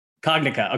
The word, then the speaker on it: Cognica: But before we go there, most of those clinical and regulatory milestones that you just mentioned, those are with respect to, is it Cognica? How do you pronounce? One of the platforms, right? – Cognica. – Okay. Got it.